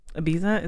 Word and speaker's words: Ibiza: Ibiza, is that how you say it? – Yeah.